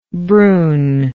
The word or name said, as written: bruhn